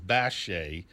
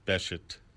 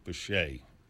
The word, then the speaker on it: Bechet: And although he pronounced his name in the Creole patois of New Orleans, Bache, he doesn't pronounce the T. And en français, that would be the way to go. I've spent a lifetime of telling people it's not Bechet, as in Beckett, but Bache, or if you like, Bache, as it was initially pronounced.